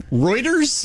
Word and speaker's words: Reuters: Rudders. – Reuters? – Yeah.